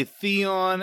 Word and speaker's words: Theon: I know a lot of people say Theon, and I think that that is the correct pronunciation.